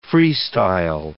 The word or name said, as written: freestyle